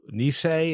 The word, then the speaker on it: Nisei: And he was in the Nisei, is that how you pronounce it, unit? Yeah. – I think so, yes.